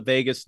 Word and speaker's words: vagus: It's called the vagus nerve. If you think of Las Vegas, it's easy to remember, even though it's spelled differently.